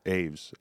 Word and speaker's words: Aves: And it's Aves, by the way. – Aves. Okay. Well, I've been saying it wrong for 20 years.